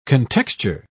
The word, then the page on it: contexture: British English